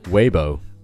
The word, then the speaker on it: Weibo: 可是它的英文你要注意一下，还真不读作微博，因为英语里面有它自己的发音体系，所以在微博这个词上，他们读成了 Weibo，Weibo，把最后一个字母 o 就读成了 o 的元音 Weibo。